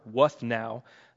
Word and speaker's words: Wuthnow: So Robert Wuthnow, whose name I find very hard to pronounce, but I did not mispronounce it. His name is Robert Wuthnow.